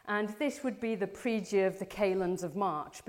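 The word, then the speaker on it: pridie: This is, apparently, somebody who speaks Latin has told me that this is pronounced as praedia.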